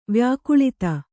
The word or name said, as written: ವ್ಯಾಕುಳಿತ